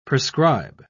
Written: prescribe

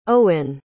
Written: eoin

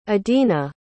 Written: edena

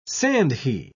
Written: sandhi

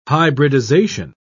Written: hybridization

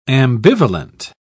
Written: ambivalent

